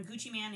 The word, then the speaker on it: Gucci Mane: We all pronounce it Gucci Mane. – Okay. – But in the South, Mane is how they pronounce it. Mane. – Oh, okay. M-A-N-E. – Okay. – But it is Gucci Mane. That's what you're going to say. Don't say Gucci Mane because you might get your ass beat.